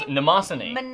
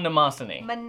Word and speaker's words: Nemosyne: Is it Nemesis? – This is Nemosyne. Maruman Nemosyne. – Nemosyne. – Nemosyne. There it is.